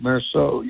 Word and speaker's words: Meursault: What'd you say, Meursault, right? Meursault, you pronounce that.